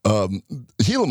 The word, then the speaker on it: Gila: As it turns out, there was a Canadian researcher that realized that the Gila monster, G-I-L-A, I think it's still pronounced Gila. – Yeah. – Or Gila monster, wherever you're from. – It's Gila.